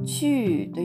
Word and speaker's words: 去: この漢字が当たるんですが、発音記号は QU と書いて、第 4 世、上から下に急降下する音になります。これはあの、チューというふうに、発音、えっと、母音がですね、うの母音みたいに見えるんですが、